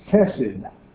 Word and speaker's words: chesed: If you find Hebrew put into English letters, an H with a dot under it will be pronounced like a K. And this great Bible word is chesed.